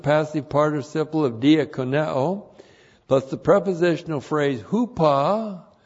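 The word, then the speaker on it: diakoneo (διακονέω): D-I-A-K-O-N-E, long O, diakoneo.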